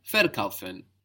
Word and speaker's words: verkaufen: Verkaufen. So this ver is quite a common addition, this V-E-R. Remember, it's a V, because the V letter in German is pronounced like F.